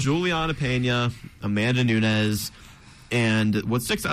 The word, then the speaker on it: Peña: Juliana Pena? No? – That is very wrong. – What is it? – Juliana Peña. – Peña, okay. – So you got to roll that in, man.